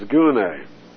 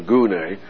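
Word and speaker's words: gune (γυνή): The word for woman in Greek is gune. We'll use the Hebrew word, isha. That's nicer, isha. See, rather than gune, because we're so geared to goon.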